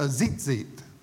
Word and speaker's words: tzitzit: This is called a tzitzit. That's how it's pronounced up on the screen. Called a tzitzit.